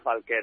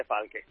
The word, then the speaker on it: Falke: Bueno, no sé qué fue un error el año pasado, la camiseta del Rayo, pues se le puso acento a la E. Y bueno, evidentemente, pues claro, la gente lo ve y pronuncia Falke, ¿no? Pero bueno, ahora ya lo arreglé y se dice Falke.